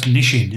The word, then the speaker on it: Nissin: Which is pronounced Nishin, isn't Nissin.